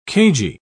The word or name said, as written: cagey